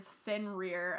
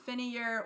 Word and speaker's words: Fenrir: It is Fenrir, apparently, and not Finnear or Fenario, whatever we had said. Whatever we said, it is, in fact, Fenrir.